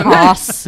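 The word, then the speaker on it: Haus: Haas, if you want to use the German Haas pronunciation.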